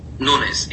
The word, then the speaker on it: Nunes: Nunes, N-U-N-E-S.